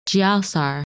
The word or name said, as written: Giausar